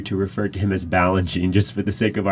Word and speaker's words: Balanchine: And it sounds like ballet, Balanchine.